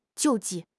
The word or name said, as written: 救济